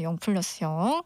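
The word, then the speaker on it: 0+0: 한로로의 이거 0 플러스 0이라고 읽으면 되는 거죠. 그때 우리 한번 얘기했었는데 0 플러스 0.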